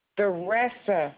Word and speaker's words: Theresa: Theresa. Well, it's spelled T-H-E-R-E-S-A. – And that was Teresa? T-A-R-E-S-S-A? – T-H-E-R-E-S-A.